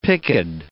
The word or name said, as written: picked